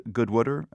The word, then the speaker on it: Goodwooder: You are a proud Goodwooder. Am I saying that right, Bev? – Most definitely.